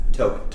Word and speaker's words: Taupe: Taupe. – Taupe. – Taupe. – Yeah. – Like the bland color taupe.